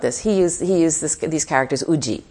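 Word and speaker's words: uji (有時): So in this case, uji is one way, yuji is another. And then if you pronounce it in, you know, Japanese, in the native Japanese, it would be arutoki.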